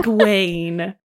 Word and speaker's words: Egwene: How do you pronounce Iggy's name? – Egwin? – Egwin? I can't do it. I can't do it. – I did it wrong. – Egwene. – There you go. And how do you spell it without looking? – E-G-W-E-N-E.